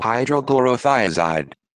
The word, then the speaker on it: hydrochlorothiazide: Luis sample tested positive for the banned diuretics chlorothiazide and hydrochlorothiazide.